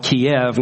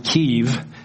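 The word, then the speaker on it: Kyiv: We arrived in, they used to call it Kiev, now I think they pronounce it Kiev, which is the capital of Ukraine.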